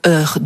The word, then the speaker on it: heur: Et puis, avez-vous remarqué, il y a une autre différence entre le heur de heurter et l'heure du bonheur, c'est que le premier commence par un H aspiré, c'est pour ça qu'on dit le heur, tandis que l'autre commence par un H muet, donc on dit l'heure.